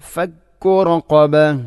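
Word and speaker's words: ഫക്കുറഖബ (فك رقبة): ഇവിടെ ഫക്കുറഖബ റഖബ എന്നുള്ള പദമാണ് ശ്രദ്ധിക്കേണ്ടത്, ഖാഫിൻ്റെ ഉച്ചാരണം. റഖബ എന്നാവരുത്, ഫക്കുറഖബ അല്ല, ഫക്കുറഖബ.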